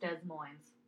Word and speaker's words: Des Moines: I was thinking Melissa Des Moines, like Iowa, but I also don't know if that's how you pronounce Des Moines. – No, it's literally pronounced Des Moines.